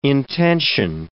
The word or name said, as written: intension